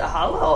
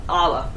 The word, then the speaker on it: Allah: Why would he poses the question, why do you still pray to, is it Hala or just Hala? – Allah.